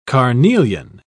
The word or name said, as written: carnelian